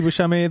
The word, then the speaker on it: Buscemi: And my name is Andy Buscemi. The last name is spelled B-U-S-C-E-M-I.